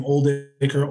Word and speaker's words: Oldacre: It's A U N D R E, last name Oldacre, O L D A C R E.